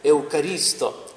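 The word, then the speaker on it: эукаристо (ευχαριστώ): В греческом языке слово «благодарность» произносится как «эукаристо».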